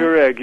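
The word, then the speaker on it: koreg (չորեկ): Do you make the koreg? Is that how you pronounce it? – Yeah, koreg. Yeah.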